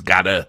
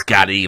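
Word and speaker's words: Gadde: In 2018, Twitter's Vijaya Gaddy, I've now realised I've been pronouncing her name wrong in all the other videos I did, because I thought it was Gadda, but it's Gaddy, like Gaddy, it's like that. Vijaya Gaddy, then head of legal policy and trust, and Kevon Rekpo, head of product, said we do not shadow ban.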